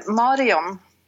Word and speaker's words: Marion: Yeah, well, you do the English pronunciation, but that's fine. – Uh-huh. What's your pronunciation? – Marion.